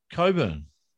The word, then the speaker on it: Cockburn: Pele's P-E-L-E. – How do you spell Coburn, Tony? – C-O-C-K-B-U-R-N. – And phonetically, what does that say? – Coburn.